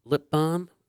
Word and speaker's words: balm: Do you pronounce the L? – I don't know. – In lip balm? – Lip balm? – No, lip balm.